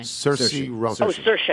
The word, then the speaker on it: Saoirse: Saoirse Ronan. – Oh, Saoirse, yeah. – She's Saoirse.